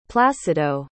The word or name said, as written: placido